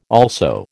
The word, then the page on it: also: American English